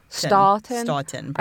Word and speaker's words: Stoughton: Stock, I want to say Stock, but it's S-T-O-U-G-H-T-O-N. – Stoughton. Stoughton. Probably. – I have to say Stock. – No, S-T-O-U-G-H. – Would be Stoughton.